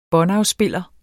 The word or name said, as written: båndafspiller